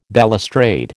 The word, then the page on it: balustrade: American English